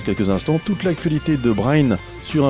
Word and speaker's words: Bren: Quelques membres sont de Languidic, Brain, ça s'écrit B-R-E-N, Dei Ganin, viens avec moi, c'est le titre.